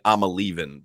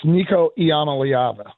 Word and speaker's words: Nico Iamaleava: I keep saying I'm a leaving, but how do you pronounce his name, John? You probably know. – It's Nico Iamaleava.